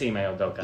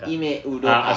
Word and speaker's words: Ime Udoka: Oh, yeah, it is Ime Udoka. – Ime Udoka.